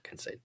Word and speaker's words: Kincaid: Kincaid.